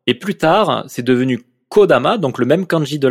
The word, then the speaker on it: Kodama: Et la, la premier changement qu'il y a eu, donc ça a été euh, Kodama avec le kanji de ki qui veut dire arbre, mais qui se prononce ko dans ce cas-là, et tama qui se prononce dama parce qu'il est associé, qui veut dire âme.